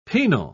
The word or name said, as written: penal